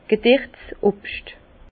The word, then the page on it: gederts Obscht: Bas Rhin; Herrlisheim